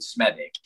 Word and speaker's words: Smethwick: Because honestly, I come from a town called Smedic in the Black Country, which is spelled Smethwick. So if somebody was to pronounce it like that, I'd be like, you got it wrong, it's Smedic.